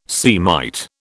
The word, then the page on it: Semite: British English